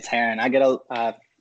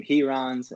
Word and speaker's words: Heron: It's Heron. Since I started, I've gotten some Herons and some other different funky pronunciations, but you nailed it, man.